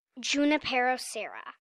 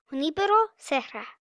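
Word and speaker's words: Junípero Serra: My teacher was pronouncing it Junipero Serra. – Which, she pointed out, is incorrect. – And how should it really be said? – Junipero Serra.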